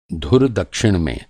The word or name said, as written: धुर